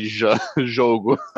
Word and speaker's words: Jogo: He's not the Hogo Bonito that people always talk about. – Did you say Hogo? – Hogo. – Pode pronunciar J, cara. You can say Jogo.